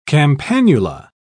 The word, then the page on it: campanula: British English